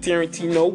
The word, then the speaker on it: Tarentino: It's YB Tarentino, Y B T A R E N T I N O. A lot of people misspell it with the A, like Quentin Tarantino, but it's Tarentino with an E.